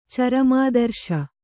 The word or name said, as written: ಚರಮಾದರ್ಶ